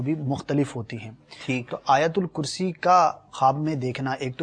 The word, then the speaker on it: آیت الکرسی: اچھا اس کا ایک تلفظ یہ ہے مدنی چینل کے ناظرین, آیت الکرسی آیت الکرسی کرتے ہیں لوگ, آیت الکرسی آیت الکرسی آیت الکرسی آیت الکرسی, یہ اس کا درست تلفظ ہے.